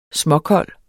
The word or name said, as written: småkold